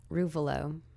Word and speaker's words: Ruvolo: Ruvolo.